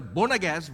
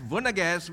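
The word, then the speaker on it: Boanerges: In James and John, Vonages, not pronounced as Bonages, Vonages, which means sons of thunder in Mark chapter 3, verse 17.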